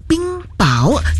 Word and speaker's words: 冰雹: 那在刚刚的上一个小时的六点钟的真的新闻当中呢，就是有两点要让你来留意的，就是一个呢就是这个读音方面，就是冰雹，刚刚我念的是冰雹，但其实真正的读音刚刚就搜寻了，也谢谢我们的前辈来提醒，是说这个冰雹原来正确的读音是叫做冰雹，这个第二个字呢是第二音的，所以呢在这个拼音上呢也要稍微的留意了，之前的这个读法呢一直以来其实真的是念错了。